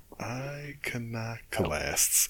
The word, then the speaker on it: Iconoclasts: A new horror game called The Inpatient, and then some indie title called, I can never know how to pronounce this, Inocolasts? I cannot remember. Iconoclasts. – I would guess Iconoclast. – Iconoclast. Okay, that makes more sense. It's got an O instead of an A, so Iconoclasts.